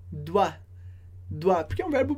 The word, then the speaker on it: doit: Je dois, tu dois, il doit, elle doit, on doit. A gente escreve diferente, com S para je e tu, com T para il, elle, on, mas a pronúncia é exatamente a mesma.